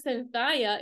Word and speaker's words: Cinthya: Well, the reason it says Cynthia is because I spell it a little bit differently than most.